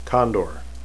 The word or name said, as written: kandor